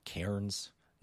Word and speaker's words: Cairns: Cairns.